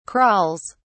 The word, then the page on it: crawls: British English